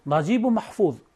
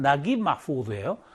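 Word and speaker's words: 나깁 마후드 (نجيب محفوظ): 나지브 마후드, 이집트 발음식으로 하면 나깁 마후드예요.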